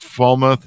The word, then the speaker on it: Falmouth: Falmouth you did very well.